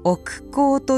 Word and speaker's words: 億劫: 大変に手間がかかり、多くの時間を費やすようなことの代名詞ともいえる億劫という言葉は、本来億劫と読むのが正しく、百千万億劫を省略したものです。